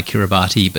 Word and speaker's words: Kiribati: Just remember, Kiribati is spelt K-I-R-I-B-A-T-I, Kiribati, but pronounced Kiribati.